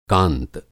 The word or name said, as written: कांत